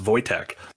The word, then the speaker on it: Wojtek: But Voitech was one of the people, uh, W O J T E K, and I know I know it's pronounced Voitech because there's a famous bear during World War II.